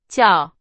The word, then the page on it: 叫: Chinese